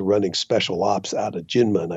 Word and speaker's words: Kinmen: Okay, so it's Jinmen, but spelled with a K.